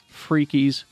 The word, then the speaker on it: Freakies: Are you pronouncing two E's? I'm hearing a two E. – F-R-E-A-K. – Okay, Freaky. – I-E-S. Freakies cereal.